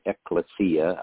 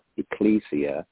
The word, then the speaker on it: ecclesia: Ecclesia. Some people pronounce it ecclesia. I pronounce it ecclesia.